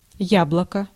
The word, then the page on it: äpple: /ˈɛ̀plɛ/